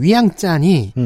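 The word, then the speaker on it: 위앙짠이 (ວຽງຈັນ): 그 때문에 현재 의 수도인 비엔티안 현지 발음으로는 위앙짠이 음.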